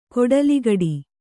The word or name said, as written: ಕೊಡಲಿಗಡಿ